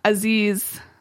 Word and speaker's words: Aziz: And Aziz... Did I say that right? – Yeah. – Okay. Okay, good. I've said it wrong before, so I just want to make sure. – That sounds right.